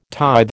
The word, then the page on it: tithe: American English